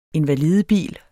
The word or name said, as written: INVALIDEBIL